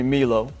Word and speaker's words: Milo: Well, first name, people call me Milo. You know, and I get that. There's a lot of international folks, so like the MI sound is me.